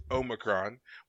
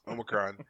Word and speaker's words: Omicron: As all Star Trek fans know, you pronounce it Omicron. We've been pronouncing it this way for decades. I'm sorry. Omicron. You're not gonna win this one. You need to go back and watch some more Trek, David. You will see it's Omicron.